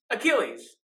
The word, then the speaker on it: Achilles: You were like, Igles. – Wait, how do you say Achilles heel? – Achilles, not Igles.